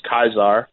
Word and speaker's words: Caesar: My Latin name in class was Caesar, but it's pronounced Kaiser.